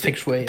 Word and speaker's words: Feng Shui: Aber Natürlich spricht man es Feng Shui.